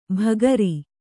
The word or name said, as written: ಬಗರಿ